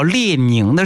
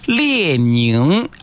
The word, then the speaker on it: 列宁: 政治老师，他给我就是他没有教我，他也是普通话测试员，他是在教室里面提到列宁的时候，我发现他发音特别慢，他说列宁啊怎么怎么样，哎，我觉得这个列宁两个字他的发音明显跟其他不一样，我一下子就 get 到，哦，原来呢了宁跟林是不一样的发音的方式，